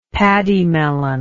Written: paddymelon

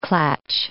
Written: klatch

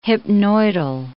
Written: hypnoidal

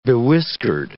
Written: bewhiskered

